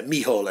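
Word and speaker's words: Mícheál: It's Michal, and it's M-I-C-H-E-A-L.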